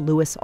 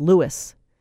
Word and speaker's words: Louis: Teachout says Louis would be the pronunciation for lighter-skinned black Creoles descended from the French, a group with higher social status in early 20th century New Orleans. – And he felt that if you pronounced his name Louis, it would be as though he were high-hatting, that he was pretending to be a Creole.